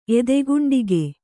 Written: ಎದೆಗುಂಡಿಗೆ

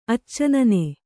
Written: ಅಚ್ಚನನೆ

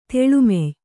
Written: ತೆಳುಮೆಯ್

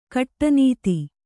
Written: ಗಾಡಿನತ್ತು

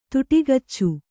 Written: ತುಟಿಕಚ್ಚು

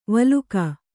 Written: ವಿಲೋಕ

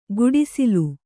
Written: ಗುಡಿಸಿಲು

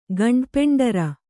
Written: ಗಂಡಪೆಂಡರ

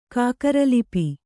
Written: ಕಾಕರಲಿಪಿ

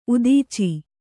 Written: ಉದೀಚಿ

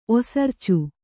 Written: ಒಸರ್ಚು

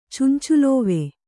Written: ಚುಂಚುಲೋವೆ

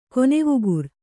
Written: ಕೊನೆವುಗುರ್

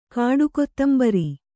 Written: ಕಾಡುಕೊತ್ತಂಬರಿ

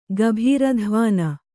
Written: ಗಭೀರಧ್ವಾನ

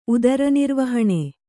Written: ಉದರನಿರ್ವಹಣೆ

